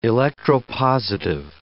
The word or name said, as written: electropositive